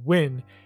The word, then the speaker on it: Nguyen: and they I've had two students, N G U Y E N, and they both said Win, and then I was looking at different pronunciations.